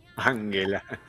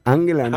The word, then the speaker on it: Ángela: Angela. – Ángela no se dice, ¿no? – Ángela.